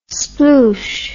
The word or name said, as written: sploosh